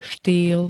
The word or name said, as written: štýl